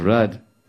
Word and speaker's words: Radha: But if you take the rad and make it, because it's spelled R-A-D-H-A. But it's Rad, not Radha.